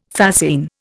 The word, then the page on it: fascine: Indian